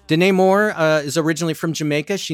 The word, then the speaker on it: Denai: This is the singer Danae Moore. Her first name is spelled D-E-N-A-I.